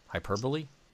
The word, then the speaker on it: hyperbole: I used to think, before I heard it in real life, every time I read it as hyperbole.